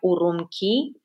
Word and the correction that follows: Urumqi: Um, Urumqi mhm. – Urumqi, przepraszam, tak to się czyta, jest ponad 960 punktów monitoringu, prawie 1000 kamer, które rozpozna, rozpoznają e, twarz i głos.